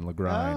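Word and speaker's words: Lagrein: We were always told it rhymes with wine, Lagrine.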